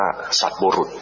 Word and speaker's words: สัตบุรุษ: สัตบุรุษสัตบุรุษอ่านว่าสัตบุรุษนะไม่ใช่สัตตบุรุษนะสัตบุรุษ